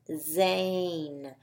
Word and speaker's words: Zane: And Zane has a long A in his name.